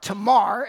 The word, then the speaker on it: Tamar: David's fourth wife bore him Absalom and Tamar. Or Tamar is probably the correct pronunciation. The A sound isn't really used in Hebrew. You're used to hearing it pronounced Tamar, so I will stick with that throughout.